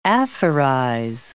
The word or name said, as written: aphorize